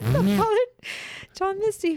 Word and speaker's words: Father John Misty: Father John Misty? Is that, did I get the name right? – Father John Misty.